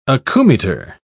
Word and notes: acoumeter: British English

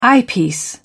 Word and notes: eyepiece: British English

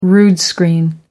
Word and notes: rood screen: American English

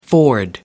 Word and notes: ford: American English

